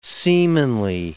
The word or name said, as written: seamanly